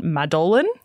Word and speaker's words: Madolyn: So in it, Kim Cattrall is the head of a beloved beauty brand, Glamorous, and her name is Madeline, but it's spelled M-A-D-O-L-Y-N, Madeline. – Madeline Addison. It's probably just pronounced Madeline, but, you know, that's how it reads.